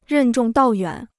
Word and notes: 任重道远: rèn zhòng dào yuǎn

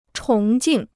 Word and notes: 崇敬: chóng jìng

